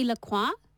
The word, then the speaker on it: La Croix: It's like that fancy, I don't know if you know it, the sparkly, fizzy, non-alcoholic drink out of America, which is spelt L-A, new word, C-R-O-I-X. – Oh. – Should be La Croix.